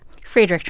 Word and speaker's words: Friedrich: So his grandfather, is it pronounced Friedrich? – Friedrich Trump.